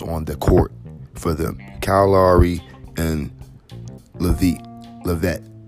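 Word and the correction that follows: VanVleet: You know, Van Vliet. Sorry, saying his name wrong.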